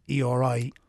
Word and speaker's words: EORI: which was to apply for something called an EORI number.